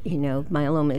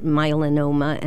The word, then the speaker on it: myeloma: I know that I'm trying to regard the time, but bone marrow cancer is very similar, myeloma is similar to leukemia and lymphoma, but many people pronounce it myelinoma, and it's not melanoma, which is skin cancer.